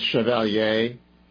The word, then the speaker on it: Chevalier: And I pronounce it the French way, Chevalier. I don't know if it's Chevalier, like the way, you know, I've been thinking about Chevrolet. That's the way I'm going with it.